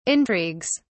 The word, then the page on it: intrigues: British English